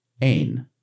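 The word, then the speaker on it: ein (ἦν): ein, the E pronounced as a long A, as in I ate the food.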